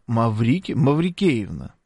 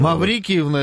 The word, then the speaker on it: Маврикиевна: Маврикиевна, это было бы странно. Отец, в смысле, вот. – Маврики. Ну, слушай, Маврикиевна, тут так наш слушатель написал. Ну, нет, он так и написал, просто ударение другое.